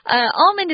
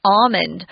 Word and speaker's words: almond: You can say it with the L, almond, but the primary pronunciation actually doesn't have an L in it. It's just almond.